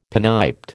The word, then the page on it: pinniped: American English